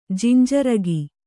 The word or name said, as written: ಜಿಂಜರಗಿ